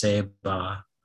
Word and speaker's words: Ceiba: The word Ceiba comes from a Taino word pronounced Ceiba.